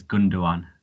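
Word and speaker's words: Gündoğan: Ilkay Gundam. – So it's Ilkay Gundogan. The G, Gundogan. The second G is silent, so it's Gundogan.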